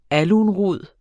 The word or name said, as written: alunrod